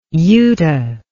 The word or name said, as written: uta